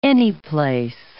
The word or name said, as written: anyplace